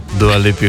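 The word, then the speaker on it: Dua Lipa: Dula Lila, Dula Lipa. – Oh my god, ti jo Dula obožuješ. Mislila, da jo znaš tudi izgovorcaj. – Dua Lipi Houdini.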